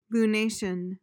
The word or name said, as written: lunation